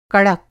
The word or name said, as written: कड़क